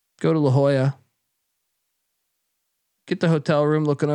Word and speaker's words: La Jolla: How do you pronounce that fucking city? La Jolla? – No, La Jolla. La Jolla, man.